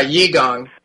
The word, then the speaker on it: Qigong: Was it Qigong? Is that how it's pronounced? – Yeah, Qigong. We do Qigong here, and some other practices as well.